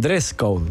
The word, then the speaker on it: dress code: Gresco. Es con Gresco. – Ah, código de... – No, con dress code.